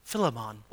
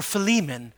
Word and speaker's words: Philemon: And there's this wonderful passage in Philemon or Philemon, depending if you're from Africa or from the United States. You can pronounce it the way you'd like. But I was told Philemon, so I'm taking that from my African brother who is named Philemon.